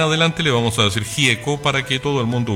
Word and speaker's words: Gieco: Raúl Alberto Antonio, voy a decirlo una sola vez en invero italiano, Raúl Alberto Antonio Gieco,